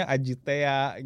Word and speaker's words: AJT: Iya, AJT gitu kan kalau ini Bandung, saya asal Bandung, AJT itu dibacanya Ajitea gitu.